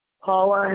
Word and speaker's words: Paula: Paula Hankins.